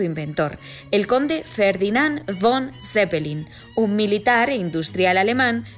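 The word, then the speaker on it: zépelin: Además, esta palabra se recoge con dos acentuaciones válidas, tanto Zeppelin con tilde en la primera E como Zeppelin con tilde en la I.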